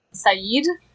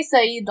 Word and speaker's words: Said: No, it is my real name, but it's actually pronounced Saeed.